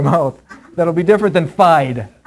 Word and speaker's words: fide: Now, actually, the word is, before we read the scripture, sola means alone, and an actual way that you pronounce the word is fide, F-I-D-E, fide.